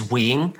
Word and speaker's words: Nguyen: Yeah, so my surname in Vietnamese, or how you're supposed to pronounce it, is Nguyen. But as a kid, I've always pronounced it as Nguyen, right? Just because it was like easier. There's no N in pronouncing it in Vietnamese, but, you know, teachers would ask me and stuff.